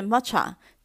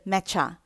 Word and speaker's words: matcha: 抹茶的发音有两种，第一个是 matcha，第二个是 matcha。